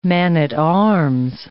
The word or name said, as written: man-at-arms